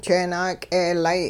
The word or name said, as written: jeenaak'eelay